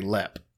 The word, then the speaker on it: Leap: Yeah, well, everybody does, because it's spelled L-E-A-P, but yeah. they said in Ireland, they don't leap, they lep. – There we go. – So it's lep.